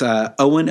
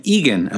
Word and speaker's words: Eoghan: In the last podcast, I really mangled the name Owen. In first place is Owen O'Keefe, spelled E-O-G-H-A-N, and I called him Egan O'Keefe.